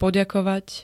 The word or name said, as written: poďakovať